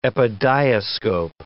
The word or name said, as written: epidiascope